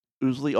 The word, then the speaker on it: Ousley: Ousley, O-U-S-L-E-Y.